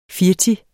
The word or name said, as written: firti